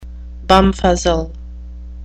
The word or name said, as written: bumfuzzle